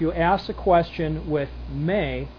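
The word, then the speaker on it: may (μή): It's pronounced may. In English, we would write it as M-E.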